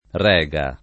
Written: Rega

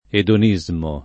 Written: edonismo

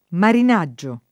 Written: marinaggio

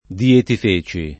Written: Dietifeci

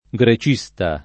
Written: grecista

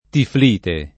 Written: tiflite

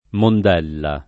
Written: Mondella

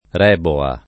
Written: Reboa

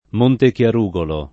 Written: Montechiarugolo

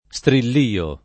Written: strillio

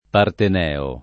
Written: parteneo

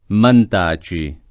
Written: Mantaci

